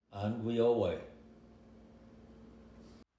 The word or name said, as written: Ongweoweh